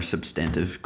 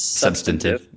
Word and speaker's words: substantive: Do you have any more substantive questions? – I believe the word is substantive.